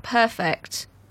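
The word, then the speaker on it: Purrfect: And don't forget that perfect P U double R. – Is there a pronunciation guide for that? Because I feel like you really need to stress it. – I, I or you got to roll your R.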